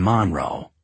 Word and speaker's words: Monroe: The Cajun fried chicken stand in Monroe, accent the first syllable, where I'd stopped for gas, determined the direction of the next several days.